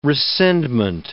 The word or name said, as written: rescindment